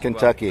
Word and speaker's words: Kentucky: Expect Kansas and Kentucky, if I say it well, if I pronounce Kentucky. – It well. – Kentucky. Just think of the Colonel Kentucky Fried Chicken.